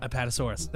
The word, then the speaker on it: Apatosaurus: Apatosaurus.